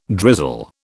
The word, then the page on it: drizzle: British English